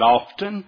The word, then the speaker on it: often: Let me ask you, how do you pronounce O-F-T-E-N? And I said, often. She said, wrong. I said, well, what is? She said, it's often. She said, don't pronounce the T.